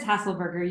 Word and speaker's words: Haselberger: Did I say it right? – Yep, it's Haselberger. You got it.